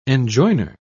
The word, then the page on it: enjoiner: British English